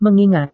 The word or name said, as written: mengingat